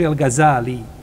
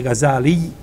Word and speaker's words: El Gazali: Imam El Gazali, rahimahullahu ta'ala, on je umro, ispravno da se kaže El Gazali, nije Gazali, nema teštida. Na kraju se kaže El Gazali, tako se ispravno izgovara njegovo ime.